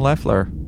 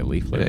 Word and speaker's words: Leffler: Robin Leffler. I think you were saying it wrong. Man, what a cutie patootie she was back. – What did I say, Leafler? – Yeah, she still is.